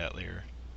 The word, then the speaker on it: Atelier: Atelier character in it though. – Atelier. Uh, what what's the official pronunciation again? Atelier. Thank you. Atelier. Atelier. Yeah. Or, Atelier, depending on where you're from.